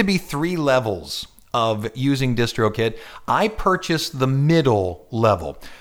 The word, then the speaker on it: DistroKid: I created an account at a website called DistroKid. D-I-S-T-R-O-K-I-D, DistroKid.